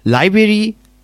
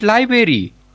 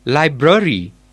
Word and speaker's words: library: I think the stress in the word library lie in the first syllable. – Not library, it's library. – Okay, sir, library.